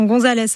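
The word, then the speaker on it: González: Alors, attention, Gonzalez avec un accent sur le A, donc bien marqué, oui. Espagnol. – Le fameux accent tonique.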